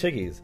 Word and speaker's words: Chickies: Like, it rhymes with Ricky's. So no, we can mention that. I mean, it's a positive thing. It's Chickies.